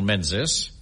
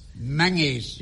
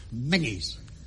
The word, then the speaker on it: Menzies: Now, quite out of the blue, we've received an invitation from Major General Menzies. – Menzies. – Yeah, I beg your pardon? – It's pronounced Menzies.